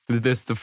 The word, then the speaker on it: Fiesta Family: А как правильно говорить? Fiesta Family? Как, как, Fiesta. А, Fiesta. Family.